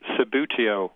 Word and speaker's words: Subbuteo: It became popular in the 1950s in England, and it had the trade name for the game was Sabutio, which is a very, very odd word. Apparently means the hobby. It's spelled S-U-B-B-U-T-E-O.